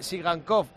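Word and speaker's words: Tsygankov: Y luego nos recalca que Sigankov y Banat son apellidos agudos, con acento en la última sílaba.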